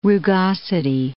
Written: rugosity

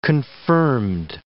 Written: confirmed

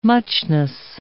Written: muchness

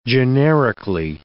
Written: generically

